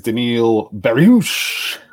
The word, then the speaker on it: Beneil Dariush: How dare you not pronounce his name correctly? It is Beneil Dariush.